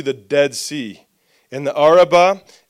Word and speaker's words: Arabah: It goes down into the valley, which is called the Arabah. I think I'm saying that right. Yeah, the Arabah,